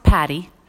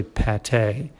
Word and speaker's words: pâté: And before we go further, though, we do want to let you know that we're, you know, it's not pronounced patty. – No, it's not pronounced patty. We don't want to embarrass you at a cocktail party like pass the patty. It's actually pâté.